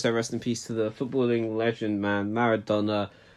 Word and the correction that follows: Maradona: Diego Armando Maradona. Yes, I don't know why, man, I pronounced it like that.